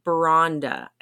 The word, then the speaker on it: Beronda: So the band, again, is September Vault, but it's a one-member band, and that member is Ben Bronda. I think that's how you say it. Bronda, B-E-R-O-N-D-A.